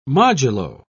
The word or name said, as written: modulo